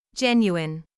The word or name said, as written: genuine